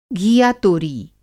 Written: घीयातोरी

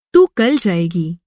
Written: तू

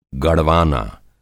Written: गड़वाना

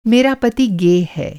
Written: गे

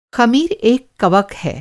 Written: ख़मीर

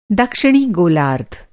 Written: गोलार्ध

